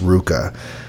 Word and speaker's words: RVCA: And the pair of shorts that this person is asking about in my photo is from a company called Ruka. R-V-C-A, pronounced Ruka.